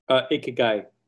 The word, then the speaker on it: Ikigai: Ikigai.